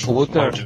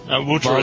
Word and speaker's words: Wouter: W-O-U-T-E-R. – Wouter. – Wouter is okay too.